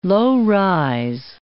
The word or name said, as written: low-rise